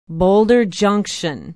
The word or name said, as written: boulder junction